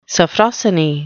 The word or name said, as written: sophrosyne